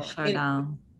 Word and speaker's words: Chardin: And I know one of my favorite quotes is, I don't know how you pronounce his name, Teilhard de Chardin or Chardin. – Chardin. – It's French. I don't know how to pronounce French.